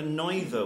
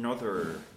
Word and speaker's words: neither: You had a neither, which is spoken nother. – Neither. It needs to be two syllables.